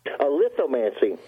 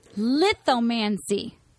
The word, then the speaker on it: lithomancy: Lithomancy. Accent on the first word. – Lithomancy. – Correct. First syllable.